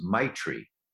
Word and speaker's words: Maitri: And third, there's a Tibetan word called, and I'm not sure I can pronounce it right, but I think it's Maitri. M-A-I-T-R-I, Maitri. Some people say Maitri, I don't know.